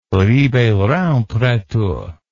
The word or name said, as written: ribeirao preto